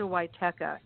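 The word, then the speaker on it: Wietecha: You just have to type Marcia Witeka.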